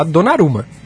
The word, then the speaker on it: Donnarumma: O, Dona, o Donnarumma. Dona, é, é, qual é a pronúncia? É, eu, digo Donnarumma. – Olha, na, é. na Eurocopa eu ouvi todo mundo falar Donaruma Mas, Donnarumma, mas é.